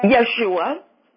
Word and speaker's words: Yeshua: How do you pronounce his name? – Yeshua.